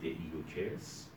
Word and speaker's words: دئیوکس: او قصه رو با دئیوکس شروع می‌کنه، با تلفظ درست یونانی دئیوکس، که 53 سال سلطنت می‌کنه.